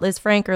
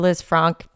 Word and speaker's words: Lisfranc: Well, you can pronounce it either way. Um, there's multiple body parts out there that different physicians will pronounce differently. So whether you call it Liz Frank or Liz Franck, doesn't matter.